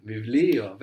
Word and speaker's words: βιβλίο: Vivlio, very good. It's written with the Vita that looks like a P, but we pronounce a V there, no? – Mm-hmm.